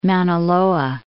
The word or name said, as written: Mauna Loa